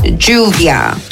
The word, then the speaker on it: lluvia: So, the way you say rain in Spanish is lluvia. So, you know, there's that makeup brand, Juvia's Palace. Yeah, literally. So, Juvia. You spell it double L-U-V-I-A, but with a J, that would give the sound.